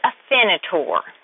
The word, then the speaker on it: Afinitor: The medication is Afinitor.